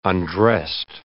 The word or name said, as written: undressed